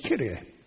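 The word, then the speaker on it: kyrios: The adjective, the word for Lord is kurios in Greek, sometimes pronounced kyrie.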